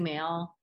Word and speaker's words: Moehl: It's a funny last spelling, M-O-E-H-L. So Chrissy Mail, everything, Chrissy Mail at Instagram.